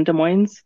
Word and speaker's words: Des Moines: We pronounce the S in Des Moines.